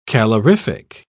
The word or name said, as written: calorific